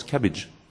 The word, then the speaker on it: CABG: Il est utile d'avoir le son aussi, ça se prononce cabbage.